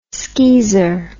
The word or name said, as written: skeezer